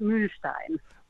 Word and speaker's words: Mühlstein: So my surname is a German surname originally. So I pronounce it Mühlstein.